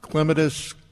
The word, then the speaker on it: clematis: Clematis, clematis, potato, potato. You know, depends on where you're from, how to pronounce it.